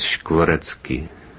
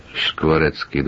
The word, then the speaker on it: Škvorecký: The pronunciation is Škvorecký. Škvorecký. The first sound is sh, not s, because...